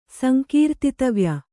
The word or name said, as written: ಸಂಕೀರ್ತಿತವ್ಯ